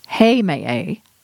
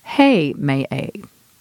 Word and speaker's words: Heimaey: There are at least 3 pronunciations of this island: Heimaey, Heimaey, and Heimaey. For this article, I'm going to use the pronunciation Heimaey.